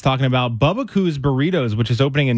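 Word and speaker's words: Bubbakoo's: Bubba Coo's, B-U-B-B-A-K-O-O-S. – Oh, just the way it sounds.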